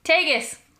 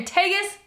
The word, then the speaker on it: Tagus: Tagus. – Oh, my God. – They're in Tagus, North Dakota. – Just think Vegas just with a T.